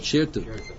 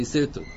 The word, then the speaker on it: sirtut: In the yeshivas, they usually mispronounce it. They call it shirtut, but it's supposed to be sirtut.